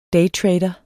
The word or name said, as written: DAYTRADER